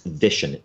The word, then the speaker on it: Vishen: It's Vishen, V-I-S-H-E-N, 6 letters.